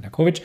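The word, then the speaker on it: Nekhorvich: der Dr. Wladimir Nerkovic, Narkovic, Narkovic wird ausgesprochen, Narkovic,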